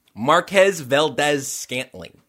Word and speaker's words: Marquez Valdes-Scantling: Marquez Valdes-Scantling.